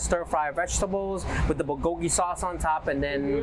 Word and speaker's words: bulgogi: Um, today's special at this event, we're gonna showcase one of my most favorite uh, item is the uh, Korean style bulgogi rice bowl. Okay. – Um, how, today, how did you say that? – Uh, bulgogi. Bulgogi. Yeah, bulgogi. As bulgogi.